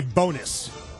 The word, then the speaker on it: Bones: It's actually pronounced Ricky Bonus.